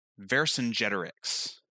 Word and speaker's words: Vercingetorix: And he pronounces it Vercingetorix.